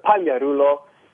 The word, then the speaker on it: Pagliarulo: But just if you want to hear it, I pronounce it Pagliarulo. It's got to, you know, you've got to roll the R's, you know. You've got to have the R effect.